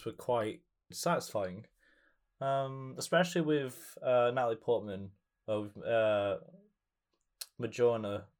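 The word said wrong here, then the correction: Mjölnir: Is it Majorna? Mjolnir? I forgot how to say the name of the hammer. – Oh, Mjolnir.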